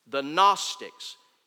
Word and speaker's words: Gnostics: The Gnostics, spelled with a silent G. G-N-O-S-T-I-C-S.